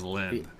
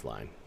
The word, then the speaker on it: Lyne: They do show, Adrian Lyne? Is that how you say his name? I always thought it was Lynn. – I believe it's Lyne.